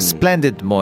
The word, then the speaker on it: splendid: ショーン・コネリーがね、ずっとジェームズ・ボンドね、お亡くなりましたけど、ジェームズ・ボンドの時に特別にちょっとアクセントがね、ちょっとスコットランド寄りの特性があって、なんかスプレンデッドも S の発音がシーなんで、スプレンデッド。 – SH になっちゃうんですよね。